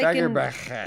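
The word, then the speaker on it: Fagerbakke: I don't think you pronounce them both. It's like Fagerbach. It's like in Balloon. You don't pronounce both of the L's, correct? – He's also...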